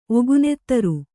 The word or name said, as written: ಒಗುನೆತ್ತರು